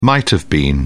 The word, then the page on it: might-have-been: British English